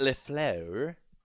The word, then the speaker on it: LaFleur: Matt LaFleur. That's how I pronounce it, if you want the correct French-based pronunciation.